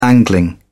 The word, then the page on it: angling: British English